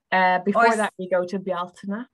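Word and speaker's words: Bealtaine: Oh, see, let me just pronounce that one for you. I'd have been like Beltane. – Yeah, some people say Beltane, and they're, you know, it's okay. – Getting a language lesson as well. This is great. I love it. So pronounce that one again for me, please. – Bjaltina. – Bjaltina. Like it. – Yeah, it beautiful. Is nice, isn't it? – Yeah. Or Beltane. People do call it Beltane.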